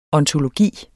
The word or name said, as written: ontologi